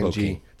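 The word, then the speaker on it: Trompo King: The Trumpo King. Right? I'm saying that right, John? – Trumpo. T-R-O-M-P-O. – T-R-O-M-P-O. King, K-I-N-G. Trumpo King.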